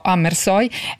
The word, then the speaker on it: Amersfoort: Gli interni domestici, per il quale Amersfoort, e la pronuncia rassicuro chi ci ascolta, io l'ho verificata con la curatrice Sandra Gianfreda e quindi dirò Amersfoort,